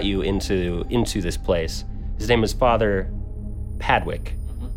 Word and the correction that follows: Padrick: Actually, it's Padrick. It's another name that I pronounced wrong.